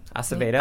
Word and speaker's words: Acevedo: I would also recommend The Poet X, and I'm going to butcher the last name, by Elizabeth Acevedo. – Acevedo. – Acevedo, thank you.